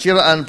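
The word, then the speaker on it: Çırağan: So the G is silent. Chiraan Palace.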